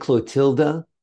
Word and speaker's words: Clotilda: I hope I'm saying the name correctly. St. Clotilda, Queen of Franks? – Yes.